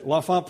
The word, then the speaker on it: L'Enfant: I don't know how Washington, D.C. pronounce it. L'Enfant? Is any? Oh, good, good, good. L'Enfant Plaza, right.